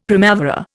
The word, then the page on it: primavera: Indian